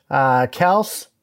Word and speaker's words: Kelce: Got Metcalf, Landry, Cook, Kels. Also, did you know that that's the correct pronunciation of Travis Kelce? Kels? Travis Kels? – No, that sounds dumb.